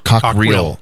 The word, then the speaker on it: Cockrill: So we have a guest with us today, David Cottrell from the American Legion. Did I say that right? – Cockrill. – Okay. I didn't want to say it wrong.